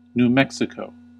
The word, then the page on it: New Mexico: /njuː mɛk.sɪ.kəʊ/; /nu ˈmɛk.si.koʊ/; /nju ˈmɛk.si.koʊ/